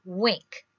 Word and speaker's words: Winc: And our new sponsor is Wink, and it's spelled W-I-N-C.